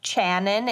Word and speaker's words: Channon: I'm pretty sure it's pronounced Channon. It's spelled C-H. Maybe it's a weird way to spell Shannon.